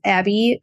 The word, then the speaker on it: Abbey: My handle is Abby Creative Co. And my name is spelled A-B-B-E-Y.